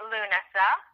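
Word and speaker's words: Lughnasa: So, in getting close to the end of the year, you have November, early November, you have Dancing and, excuse me if I pronounce this wrong, Lugnasa? – No, Dancing at Lunasa.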